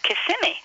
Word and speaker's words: Kissimmee: This is supposed to be on the middle syllable, and it's actually pronounced Kissimmee.